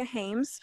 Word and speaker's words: Haymes: Um, my Instagram is Rebecca Haymes Photography.